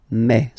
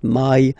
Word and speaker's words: mai: In French, remember, when you combine this A and then E vowels, you get the sound A, A. So really open A, okay? And then you pronounce it me, me. Okay, so don't pronounce E, don't try to pronounce it. No, it's me, mai. So it's not my, it's mai.